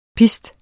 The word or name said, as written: pist